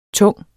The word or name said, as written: tunge